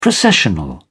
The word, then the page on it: processional: British English